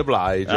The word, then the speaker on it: Blige: Ogni tanto tanto certo, nell'interfono si fa sentire e dice avete sbagliato, si dice Blige.